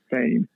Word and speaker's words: Fane: Fame. F-A-N-E. Fame.